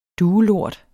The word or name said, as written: duelort